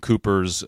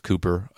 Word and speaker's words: Cowper: that's William Cowper's um, "God Moves in a Mysterious Way." Um, it's spelled like Cowper, but it's it's pronounced Cooper,